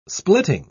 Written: splitting

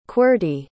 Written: qwerty

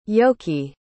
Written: yoki

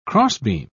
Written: crossbeam

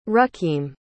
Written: rukeem

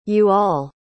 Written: you-all

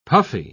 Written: puffy

